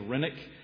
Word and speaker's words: Renwick: James Renwick, as we say. I think the Scottish pronunciation is Rennick. James Rennick, this Scottish covenanter, the last to officially die as a martyr.